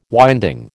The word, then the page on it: winding: American English